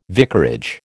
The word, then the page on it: vicarage: American English